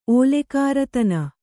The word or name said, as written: ಓಲೆಕಾರತನ